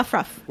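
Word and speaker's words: aufruf: The Afruf, that's what it's called. – Afruf, yeah. – Afruf.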